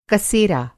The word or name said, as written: कसेरा